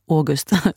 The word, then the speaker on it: August: Jag brukar ofta sjunga bakishetens lov, eftersom jag någon gång läst att August Strindberg, märk väl mitt uttal, August, ja. August Strindberg skrev de bästa verken när han var bakfull.